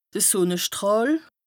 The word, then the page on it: de Sùnnestràhl: Bas Rhin; Strasbourg